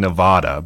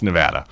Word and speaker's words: Nevada: You were close. Very close. You weren't saying Nevada, but, it's Nevada.